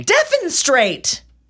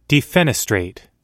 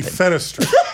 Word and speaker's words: defenestrate: Your pronunciation is so wrong. Try one more time for me. – Defenstrate. – Defenestrate. – Well, that's stupid. – Defenestrate.